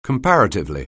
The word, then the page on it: comparatively: anglais